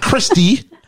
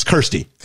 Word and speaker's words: Kirsty: Kirsty rhymes with... I don't know. – Thirsty or Hursty or Worsty. It's not Christy. It's not Krusty. It's Kirsty. Yeah.